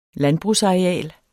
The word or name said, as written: landbrugsareal